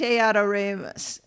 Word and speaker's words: adoremus: "Venite adoremus," Donna picked up the speed. "Venite adoremus," the Amish replied, pronouncing the second word with a particular flatness. "Adoremus," Donna corrected. "Moose. Moose, adore moose."